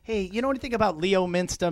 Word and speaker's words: Leominster: L-E-O-M-I-N-S-T-E-R. – Oh, that's a T. Wow. Leominster. Right? I don't know. Leominster? – She's close. She's getting close. – The correct way to pronounce it is Leominster.